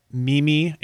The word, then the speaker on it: Mimi: Mimi is Mimi. The only difference is in Italian, you accent the second syllable instead of the first.